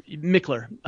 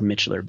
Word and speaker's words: Michler: Michler. – Michler. – But yeah, it's my Instagram is Ryan, R-Y-A-N, and Michler is M-I-C-H-L-E-R. So it looks like Michler, but pronounced Michler.